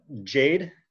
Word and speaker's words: Jade: So Jade. Like the color Jade is usually what I pronounce it.